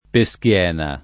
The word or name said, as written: Peschiena